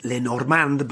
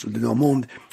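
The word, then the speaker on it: Le Normand: Well, because of Robin Lenormand, who's pronounced Lenormand by all the Spanish commentators, but of course he's pronounced Lenormand.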